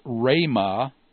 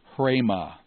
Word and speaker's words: rhema: So it's rhema, not hrema. You don't add an H sound to the beginning of a rho initial word.